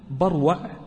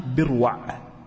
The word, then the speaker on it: بروع: أولا، بروع جاء أن أصحاب الحديث يضمطونها بروع بكسر الباء، والصواب فتح الباء، قالوا بروع مثل جدول، مثل جدول، يعني الناس ما تنطق جدول تنطق بروع.